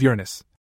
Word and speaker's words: Uranus: Cherry does love Uranus. I said, using that classical pronunciation, not the one my teachers always tried to get us to say.